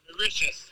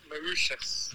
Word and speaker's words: Mauritius: I'm pretty sure it's pronounced Mauritius, not Mauritius.